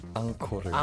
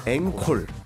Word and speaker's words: 앙코르: 예를 들어 ENCORE 앙코르. – 앙코르. – 네, 그러면 앙코르. – 앙코르. – 앵콜 아니죠? 앙코르입니다.